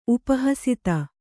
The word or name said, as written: ಉಪಹಸಿತ